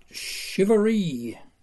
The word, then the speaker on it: Charivari: And yes, I know I should pronounce it Shivery.